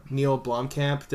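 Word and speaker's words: Neill Blomkamp: I just bought, like, a Neil Blomkamp. Did I pronounce that correct? – You did.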